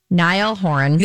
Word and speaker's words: Niall Horan: So she broke up with, I'm always going to say it wrong, Niall Horan.